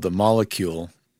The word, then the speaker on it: Molekule: But there's a company called The Molecule, and it's spelled MOLEKULE.